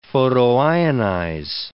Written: photoionize

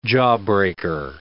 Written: jawbreaker

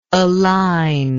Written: aligner

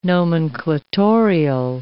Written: nomenclatorial

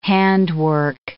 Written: handwork

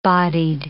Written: bodied

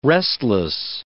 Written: restless

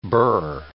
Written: burr

